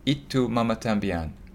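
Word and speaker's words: Y Tu Mamá También: Alfonso Cuarón's It Tu Mama Tambien. Have I said that right? – You have. Well done.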